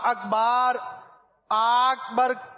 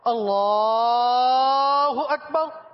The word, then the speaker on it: اللہ اکبر: جیسے امام صاحبان کو آپ نے دیکھا ہوگا جو صحیح خواہ ہوتے ہیں وہ اسی طرح, وہ آپ اکبار اکبر کہتے ہوئے آپ کو امام نظر نہیں آئیں گے, صحیح تلفظ کے ساتھ جو امامت کرواتے ہیں وہ لام کو ہی کھینچتے ہیں اللہ اکبر, اسی طرح کی تکبیریں آپ نے سنی ہوں گی.